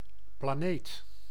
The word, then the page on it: planète: France; /pla.nɛt/